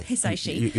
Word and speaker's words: Hisaishi: The piece of music, My Neighbor Totoro, by Joe Hisaishi. Have I said that correctly? Hisaishi? I don't know. Maybe. – Yeah, I think you're right. – Hisaishi. – You pronounced it right.